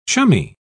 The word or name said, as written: chummy